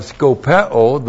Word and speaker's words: skopeo: So we have skopeo, the verb skopeo, S-K-O-P-E, long O.